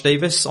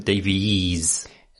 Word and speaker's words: Davis: Maybe I should pronounce it Davies. – Davies. – But I won't.